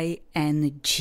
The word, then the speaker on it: ING: Důležité je vědět, že I vyslovujeme jako I, stejně jako anglické zájmeno já, také vyslovujeme I, N, stejně jako v češtině N, a pozor na G, v angličtině G, ING.